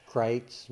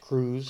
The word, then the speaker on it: Kreuz: Kreitz, which is often pronounced Cruz. Kreitz and Lockhart. – K-R-E-U-Z, Kreitz. It's not, it's Czech, German, just roll with it.